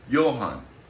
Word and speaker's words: Johann: Johann, because that is, you got to do German pronunciation. Johann.